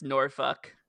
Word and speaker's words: Norfolk: But where Kip and Penelope continue to convince Justin Roberts that, no, actually, it is pronounced Norfolk.